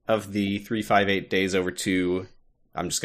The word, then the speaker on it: 358/2 Days: Do you like that this video game is spelled 358 over 2 days, but it's pronounced 358 days over two?